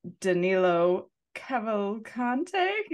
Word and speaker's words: Danilo Cavalcante: I am going to butcher this name, but Danilo Cavalcante. Can you say it? – I think you said it right.